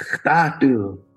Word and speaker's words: khtatu: And then she'd say, it's khtatu. So khtatu is the the fast way of pronouncing that. Like, Khtayetu turns into Khtatu.